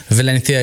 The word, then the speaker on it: Valencia: I went to Valencia and if, in case anyone's questioning why I say Valencia and not Valencia, it's because Valencia is the correct way to pronounce it. I was schooled by my Spanish friend.